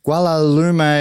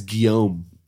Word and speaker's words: Guillaume: Guillaume Laplace breaking into the escargot farm. Now, how many words did I pronounce wrong in that? – Well, it's Guillaume.